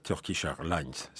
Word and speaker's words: Turkish Airlines: A fait interdire sur Turkish Airlines, c'est comme ça qu'il faut prononcer l'anglais, le film Un siècle magnifique,